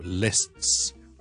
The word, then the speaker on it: lists: Last say, last say, and that is how you pronounce S-T-S. 俾多个例子大家，譬如我话 list, L-I-S-T, 一个列表啦，list of something。如果我系一个众数嘅时候咧，一一扎 list 咧，我就会 lists。